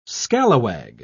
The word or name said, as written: scalawag